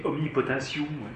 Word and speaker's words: omnipotentium: Mais on dit omnipotentium. – Oui. – Et pas tium. – Omnipotentium. Voilà. Sium. – Comme consortium. – Consortium, oui. Ouais.